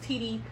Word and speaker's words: Tedie: I'm on Instagram as Doula Tedie, and I am on Facebook as Tedie Pasley, and that's T-E-D-I-E, pronounced like touchdown, Tedie. Pasley, P-A-S-L-E-Y.